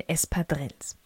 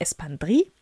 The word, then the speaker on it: Espadrilles: So, und jetzt holen wir mal so richtig das Urlaubsfeeling nach Hause und das machen wir am besten mit den Espadrilles. Das ist die spanische Aussprache. Diese Schuhe kommen nämlich sowohl aus Spanien als auch aus Frankreich. Wenn du die französische Aussprache haben möchtest, dann natürlich Espadrilles.